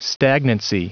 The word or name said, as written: stagnancy